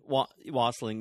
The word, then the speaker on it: wassailing: Anyway, and I didn't also realize there's something called geising, which is similar to wassailing. Is that how you say it? – Wassailing. – Wassailing, yeah.